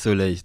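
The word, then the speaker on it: Soley: Alors, c'est Soleil. – Bah, Soleil, pourquoi je veux dire Soleil maintenant voilà. Soleil, oui. Voilà. Sauf que exactement. – Ça s'écrit EY. – EY, c'est l'orthographe créole.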